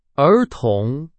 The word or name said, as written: 儿童